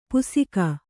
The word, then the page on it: ಪುಸಿಕ: pusika